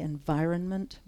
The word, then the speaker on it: environment: So, and the emphasis here is on the second syllable, the environment, right?